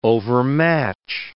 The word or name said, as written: overmatch